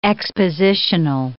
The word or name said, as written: expositional